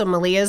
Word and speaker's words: Malia: So Malia is my middle name. It's pretty. Maria, but with an L.